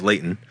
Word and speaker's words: Layton: Sorry, I said that wrong. It's Layton. – Next to the mountains. – By the mountains. – That's where I'm from, and it should always be said that way.